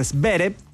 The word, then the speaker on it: Zverev: Y en otras noticias internacionales, Alexander Zverev, o Zverev, como dice él que se pronuncia, se quedó con el título del US Open.